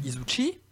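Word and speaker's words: Izushi: Izuchi, je sais pas si je le prononce bien, euh, Bosdick. oui, oui, c'est très bien,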